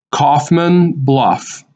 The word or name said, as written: Coffman Bluff